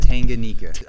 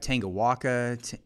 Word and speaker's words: Tanganyika: Did I pronounce that correctly? – Tanganyika. I got Tangawaka, Tanganyika.